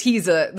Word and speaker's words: Heeza: Because it could be easy to hear you read Mr. He's a Good Host and not know that it's spelled Mr. H-E-E-Z-A. – Like, his name is Heza, the way Emily would have her characters have names that were close, but not quite.